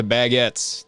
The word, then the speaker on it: baguette: But everyone did walk around with the baguettes, those big-ass breads.